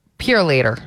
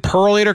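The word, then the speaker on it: Purolator: The way my parents pronounce that word is just so goofy. They say Purelator. You see, Perlator's coming to pick that up. – Nope. – Did Perlator come by? Emily, you got to stop saying it like that. You can't say it like that.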